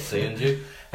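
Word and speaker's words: Söyüncü: That's it, Soyuncu.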